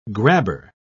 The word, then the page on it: grabber: British English